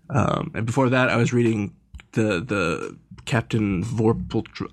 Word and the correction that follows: Vorpatril: I can never pronounce any of these names. – Vorpal Patrol. That's all it is.